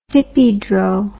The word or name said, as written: dippydro